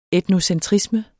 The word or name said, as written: etnocentrisme